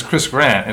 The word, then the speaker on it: Grant: My name is Chris Grant, and it rhymes with Chris Platt.